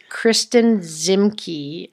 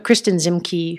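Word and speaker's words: Kristin Ziemke: And in fact, I have a new girl crush because this Kristen Zimke, I think is how you say her name, Kristen, K-R-I-S-T-I-N, Zimke, Z-I-E-M-K-E.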